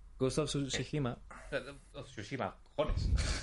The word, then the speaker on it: Tsushima: ¿Cómo se dice, tío? Ghost of Tsushima... – Tsushima. Tsushima. – Tsushima. Vale, Tsushima. Es que no recuerdo bien.